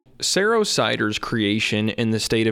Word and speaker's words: Saro: The name Saro Cider, spelled S-A-R-O, is pronounced like the word arrow.